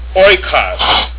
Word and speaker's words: oikos: The next Greek diphthong, where two vowels are combined, is oi, omicron iota, as in oil, or in oikos, meaning house.